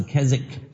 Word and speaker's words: Keswick: 第五波呢叫做凯西克，凯旋的凯无锡的锡克服的克，凯西克 Kesick 这个字是 K-E-S-W-I-C-K 这个 W 是不发音的不是 Keswick 是 Kesick。